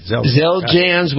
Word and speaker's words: Xeljanz: It's X-E-L-J-A-N-Z.